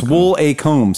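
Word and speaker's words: Woolacombe: Woolcombe, no. Devon? Yeah. – I'm not sure how you pronounce that. – It's Wool-a-Combe. Speaking of wool. – Woolcombe.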